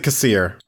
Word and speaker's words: Kassir: Then we have Kaya Kassir. There's a Kasser. See, this is the problem. – I pronounce it Kassir.